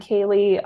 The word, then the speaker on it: Kaely: It's at Kaely RD. My name is spelled pretty weird. It's K-A-E-L-Y and then RD.